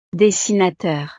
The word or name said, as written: dessinateur